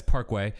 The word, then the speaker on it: PKWY: It's spelled PKWY. We all know that that's Parkway.